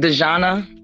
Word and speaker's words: Dajana: He said Diana spelled D A J A. So it's spelled almost like Dajana, but it's the Croatian way of spelling Diana.